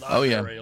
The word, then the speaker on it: library: Library. – Yeah, oh, yeah. – Oh, yes. – Library. Mm-hmm. Yeah, there's no library. There's no berry in library.